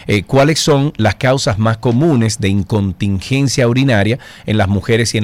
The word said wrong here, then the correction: incontinencia: Y es incontinencia urinaria. – ¿Cómo se llama? – Incontinencia. – Incontinencia, ok, ok, lo dije mal. Incontinencia.